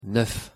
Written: neuf

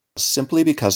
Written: simply because

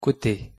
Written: cote

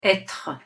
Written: etre

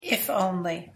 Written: if only

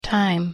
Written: time

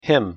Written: him

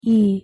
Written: ㅣ

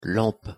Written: lampe

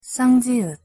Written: ㅉ

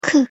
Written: ㅋ

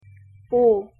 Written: ㅗ

